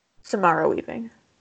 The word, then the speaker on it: Samara Weaving: Am I saying her name correctly? Samira Weaving. Samara Weaving.